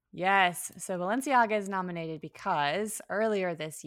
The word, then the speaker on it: Balenciaga: Balenciagas. What is the brand? – Balenciaga. – You take this one, but please help me out. Balenciaga.